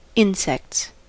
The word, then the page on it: insects: American English